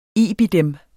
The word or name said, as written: IBID